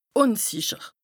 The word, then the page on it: ùnsìcher: Bas-Rhin; Strasbourg